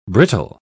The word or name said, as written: brittle